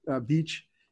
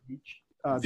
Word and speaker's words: Veech: So Veach is spelled V-E-E-C-H. So it's David Veach. – V as in Victor. I gotta make sure everybody knows it's a V as in Victor, not a B.